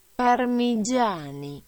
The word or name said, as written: Parmigiani